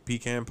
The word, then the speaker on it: pecan: My favorite now is like pecan pie. – Oh, really? – Yeah. – Okay. – I think I said that right. – No, you did.